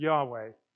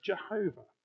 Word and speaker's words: Yahweh: This is why the holy name of God is YHWH, YHWH in Hebrew, sometimes pronounced Yahweh, sometimes previously pronounced Jehovah, actually not pronounced at all by observant Jews, partly because the correct pronunciation is not completely clear, Hebrew vowels being what they are, and they want to be absolutely certain they are not saying the personal name of God wrongly.